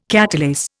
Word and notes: catalase: Indian

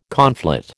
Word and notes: conflate: American English

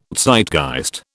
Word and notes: Zeitgeist: British English

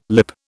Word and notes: lip: British English

